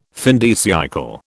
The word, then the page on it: fin de siècle: British English